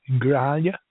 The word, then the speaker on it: Grijalva: The next guy is called Raul Gravalia. I'm probably not pronouncing that right, because I guess he is pronounced H in Spanish. Grijalva.